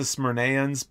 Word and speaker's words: Smyrnaeans: And I'm going to call them the Smyrnans because that's the easiest way for me to pronounce that. I know it's the Smyrnaeans, but please don't correct me on that.